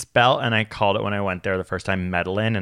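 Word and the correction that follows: Medellín: and it was like laughed off the face of this earth, but it's Medellin.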